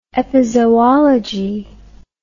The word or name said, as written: epizoology